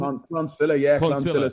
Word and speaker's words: Clonsilla: You grew up in the North Dublin suburb of Clonsilla. Is that how you pronounce it? – Clonsilla.